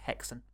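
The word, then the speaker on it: Häxan: Haxan. Pronounced Hexan.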